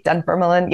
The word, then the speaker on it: Dunfermline: They arrived at Dunfermline. Did I say that correctly? Dunfermline, yes.